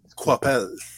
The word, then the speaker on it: Coipel: Es, es, es, que es Coipel, ¿no? ¿O es Coapel? – Coapel.